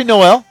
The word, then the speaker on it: Noelle: What is the correct pronunciation? N-O-E-L. Noelle or Noel? Noelle. – Probably Noelle. – Noelle for a lady.